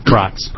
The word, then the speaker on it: Brotz: My name is Patrick Brotz, pronounced like a bratwurst, just for your future reference.